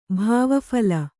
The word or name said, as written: ಭಾವಫಲ